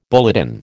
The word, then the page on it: bulletin: American English